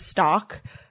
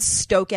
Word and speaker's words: Stoke: She also liked the Stoke, which I used to pronounce as stock. – I noticed in your recent vlog, you kept saying stock. I was like, what? – I know. I was like, and then I said Stoke eggs.